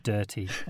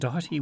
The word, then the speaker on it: Doherty: I've been introduced as Richard Doherty on many, many occasions, which I like, but is incorrect. So my name, I'm Richard Doherty, Workday Product Marketing Senior Director.